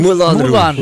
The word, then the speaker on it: Moulin Rouge: Moulin Rouge. – Moulin Rouge.